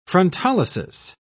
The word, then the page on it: frontolysis: British English